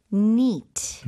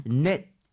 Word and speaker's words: neat: Neat. Neat. – N-E-A-T. T가 네. 되겠고요. 선생님 이거 발음할 때 net 이렇게 해야 될까요? neat 이렇게 해야 될까요? – 이 발음을 강하게 해주셔서 neat.